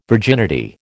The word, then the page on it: virginity: American English